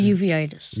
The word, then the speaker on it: uveitis: Uveitis.